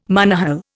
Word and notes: monohull: Indian

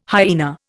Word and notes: hyaena: Indian

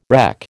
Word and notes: reck: American English

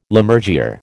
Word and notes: Lammergeyer: American English

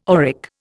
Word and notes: auric: Indian

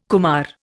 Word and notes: kumara: Indian

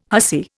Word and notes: hussy: Indian

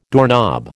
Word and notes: doorknob: American English

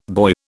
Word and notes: buoy: British English

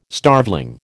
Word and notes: starveling: American English

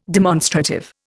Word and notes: demonstrative: Indian

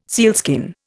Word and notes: sealskin: Indian

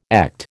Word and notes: ECT: American English

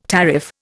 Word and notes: tariff: Indian

